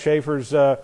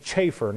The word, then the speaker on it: Chafer: And so I found out from one of Schaefer's prize students that he pronounced his name Chafer, not Schaefer, Chafer, C-H, Chafer.